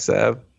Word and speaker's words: salve: They probably used black salva. – Oh, no. Oh, no. I just saw the big toe. – No. – How many times you say that wrong? – Say what? – Black salve.